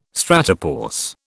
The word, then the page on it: stratopause: British English